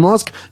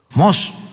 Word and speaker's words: Musk: Musk. – No, no, menso. Así no. – Haga de cuenta que va a decir mosca y le quita la A.